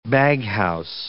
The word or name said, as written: baghouse